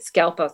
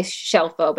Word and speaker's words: Scelfo: My last name's Italian, and my grandmother wanted to assimilate many years ago, and we started mispronouncing it Scelfo. So I sometimes say Scelfo, but anything, it's fine.